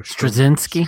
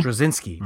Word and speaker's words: Straczynski: Michael Straczynski. – Straczynski.